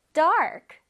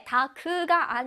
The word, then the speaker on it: dark: 자, 어두운은 영어로 dark, dark. 우리 친구들 해보겠습니다. dark, dark. 다크라고 우리 친구들 알고 있을 텐데 다크가 아니라 AR 발음을 정확하게 해줄게요.